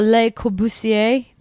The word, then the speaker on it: Le Corbusier: And there's a Le Corbusier, did I say that right? – Sure.